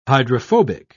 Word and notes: hydrophobic: British English

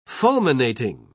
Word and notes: fulminating: British English

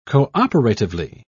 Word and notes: cooperatively: British English

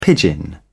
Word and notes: pidgin: British English

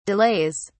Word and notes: delays: British English

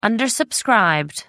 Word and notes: undersubscribed: American English

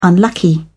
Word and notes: unlucky: British English

